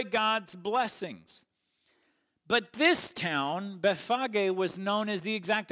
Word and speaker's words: Bethphage: Now, people pronounce it Bethpage or Bethphage. It's a P-H-A-G-E at the end, but in Greek, it's Bethphage.